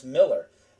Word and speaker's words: Millar: And it was funny because while we were watching it, I kept telling my wife, I kept saying, it's Millar, but it's pronounced Miller.